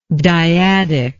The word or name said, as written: diadic